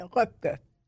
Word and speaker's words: Röpke: Röpke. – Röpke. – Röpke. – If you say it in German, it's Röpke.